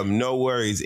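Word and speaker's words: NxWorries: So it's a group called No Worries. It's spelled NX Worries or whatever.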